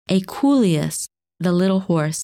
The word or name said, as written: Equuleus